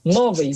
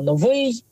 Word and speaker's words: новий: Ну, скажімо, говорить випадок замість літературного випадок, новий замість літературного новий.